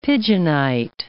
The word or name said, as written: pigeonite